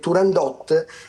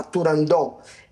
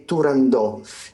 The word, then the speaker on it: Turandot: interessantissimo articolo della Scala, del Teatro La Scala, dove sembra che non sia corretto dire Turandot, ma Turandò. E qui abbiamo addirittura la fonte più vicina a noi che è la Rosa Raisa, la prima principessa di Genova della storia, che dice proprio espressamente che non si dice, non si pronuncia con la T finale, ma invece è realmente Turandò.